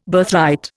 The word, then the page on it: Birthright: Indian